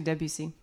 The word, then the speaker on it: Debussy: I figured out how to say his name. – It's actually Debussy.